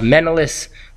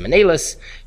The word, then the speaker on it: Menelaus: by the name of Menelaus. Some pronounce him Menelaus.